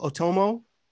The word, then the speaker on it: Otomo: And to give a little brief sort of like publication history, Akira is by Katsuhiro Otomo.